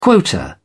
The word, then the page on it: quota: British English